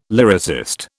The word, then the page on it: lyricist: British English